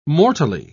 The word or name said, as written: mortally